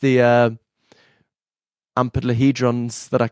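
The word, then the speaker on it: amplituhedrons: It's basically like amplitude. Just forget the amplitude, D. Amplitude and then just say hedron. – Amplitudehedrons.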